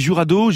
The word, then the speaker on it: Jurado: On dit Jurado, J-U-R-A-D-O, Jurado.